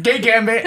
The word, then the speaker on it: Kei Gambit: So Donnie Fan Comedy and at everything, and Kay Bambit, everything for him. – Kay Bambit. – Kay Gambit. – Just butchered my name like that. – Kay Gambit at everything.